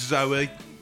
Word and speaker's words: Zooey: it's spelt Zooey, but it's Zoe Deschanel.